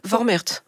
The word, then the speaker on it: Vormärz: Vormärz.